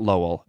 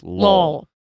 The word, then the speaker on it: Lowell: Lowell is like one syllable in this way. Not Lowell. It's Lowell.